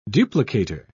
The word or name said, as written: duplicator